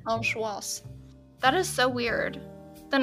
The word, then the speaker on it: angoisse: Angoisse. I don't know how to say it even. – Angoisse.